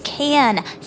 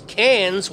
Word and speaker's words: Cannes: It's Cannes. Silent S, okay? And you can't drive there. – First up, it's Cannes with a loud S.